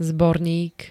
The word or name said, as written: zbornik